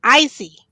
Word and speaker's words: Isy: It's spelled I-S-Y as Izzy, but it's Icy.